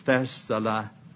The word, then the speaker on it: Thessalonica: But I asked a man when I was over there in Athens, I asked him how to pronounce that town so I'd know for sure how they did it. He said it's Thessalonica. Thessalonica, not Thessalonica, or not Thessalonica, as I said. Thessalonica. We quite often pronounce it Thessalonica, but he says Nica rather than Nica.